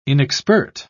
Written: inexpert

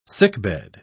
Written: sickbed

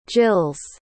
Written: gill's

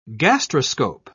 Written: gastroscope

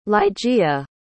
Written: ligeia

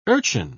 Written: urchin

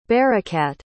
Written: barakat

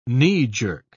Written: knee-jerk

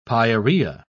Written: pyorrhea

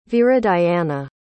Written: viridiana

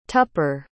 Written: tupper

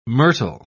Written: myrtle

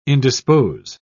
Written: indispose

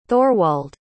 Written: thorwald

